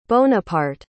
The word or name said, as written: Bonaparte